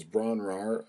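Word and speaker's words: Bron-Yr-Aur: That, of course, was Bron Rahr, which I believe is the proper pronunciation, and that was recorded by myself.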